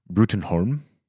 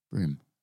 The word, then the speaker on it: Broom: Number two, we've got the Hellboy reboot has cast Ian McShane as Professor Brutenholm. – Broom. I literally wrote there. It's pronounced Broom.